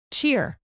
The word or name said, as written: cheer